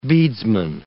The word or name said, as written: beadsman